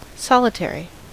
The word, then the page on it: solitary: American English; [ˈsɒl.ɪ.ˌtɛɹ.i]